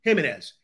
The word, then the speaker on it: Jimenez: How do you pronounce it? – Jimenez.